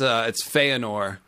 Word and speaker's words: Fëanor: Oh yeah, I said Fëanor. It's Fëanor. So says Lucifer means Lightbringer.